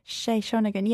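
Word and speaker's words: Sei Shōnagon: I don't know if I'm pronouncing her oh, name right. – Shay Shonagon, yeah.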